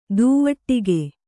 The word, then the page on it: ದುವಟ್ಟಿಗೆ: duvaṭṭige